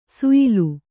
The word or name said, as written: ಶ್ಯಾಲ